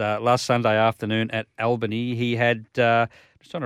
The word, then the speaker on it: Albany: Albany or Albany? Albany. – Albany. – They pronounce it Albany.